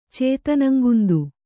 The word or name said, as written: ಚೇತನಂಗುಂದು